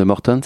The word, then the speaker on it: Morton: Tu es blessé depuis le mois de mars avec un syndrome de Morton, c'est ça, on prononce comme ça, mm. euh, à l'anglaise, qui euh, bah, se manifeste par euh, des, douleurs au pied.